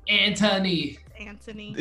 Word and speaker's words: Anthony: If you keep saying Anthony the entire time, we're probably going to smack you. It's Anthony. – Is there not an H in there? – But they pronounce it Anthony. – Because they don't. – Pronounce it like a civilized person that you are.